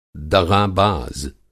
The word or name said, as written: दग़ाबाज़